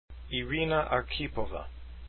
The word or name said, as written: arkhipova